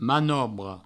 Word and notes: manobra: manobro

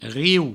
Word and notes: riu: rieu; Provençal

